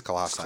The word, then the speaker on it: Colossi: I don't know how to say it. – It's Colossi.